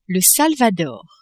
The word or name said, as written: Salvador